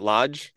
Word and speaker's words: Loge: You think of like the Lodge, is it Lodge? L-O-G-E, yeah. Those guys, is that how you pronounce it?